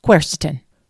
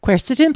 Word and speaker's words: quercetin: Today, let's grab a hold of the flavonoid quercetin.